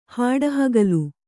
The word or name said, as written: ಹಾಡುಹಗಲು